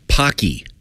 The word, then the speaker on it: Paqui: It's about the size of a hand, and apparently it's pronounced Pocky. – Yeah. – I've been told it rhymes with hockey. – Yes. – P-A-Q-U-I.